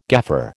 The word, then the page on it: gaffer: American English